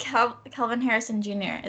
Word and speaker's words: Kelvin Harrison Jr.: I did Kelvin Harrison Jr. Is that how you say it? – Yes.